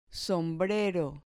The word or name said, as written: sombrero